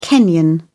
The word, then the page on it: kenyan: British English